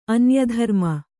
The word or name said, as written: ಅನ್ಯಧರ್ಮ